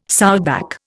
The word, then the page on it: sowback: Indian